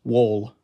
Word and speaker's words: Woll: Not Woll, but Woll, W-O-L-L.